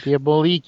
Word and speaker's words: Les Diaboliques: And a number three would probably be that the French version of Les Diaboliques. I think that's how you pronounce it. – Diabolique, yeah, from 55. Diabolique, yes, yep. Thank you.